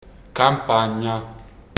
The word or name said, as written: campagna